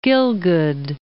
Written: Gielgud

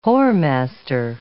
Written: whoremaster

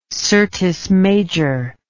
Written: syrtis major

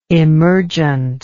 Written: emergentness

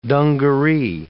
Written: dungaree

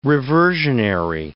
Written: reversionary

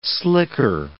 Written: slicker

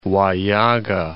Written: Huallaga

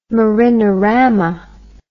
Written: marinorama

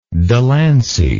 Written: delancey

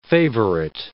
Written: favorite

